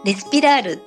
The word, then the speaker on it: RESPIRAR: 私の LINE ID はですね、アルファベット小文字で、 RESPIRAR、レスピラールって読むんですけど、になります。